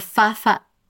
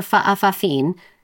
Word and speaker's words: fa'afafine: "For Fafa..." I keep saying this wrong. For fa'afafin. I did practice, but that didn't work. – No, I believe you. – For fa'afafine, I think.